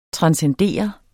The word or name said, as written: transcendere